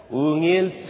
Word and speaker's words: ungil: So the word is spelled U-N-G-I-L. That's good. U-N-G-I-L, you say ungil.